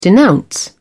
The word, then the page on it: denounce: British English